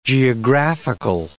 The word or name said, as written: geographical